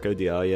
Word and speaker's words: Audiard: Yeah, I think you've got Audiard right as well. – Have I? – Yeah, I'm pretty sure that's how you say it. Jacques Audiard, yeah.